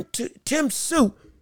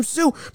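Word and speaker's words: Tszyu: And also, covering the world of boxing, we have to discuss what's going on with Tim Tsui. I got the name right, yeah. Tim Tsui.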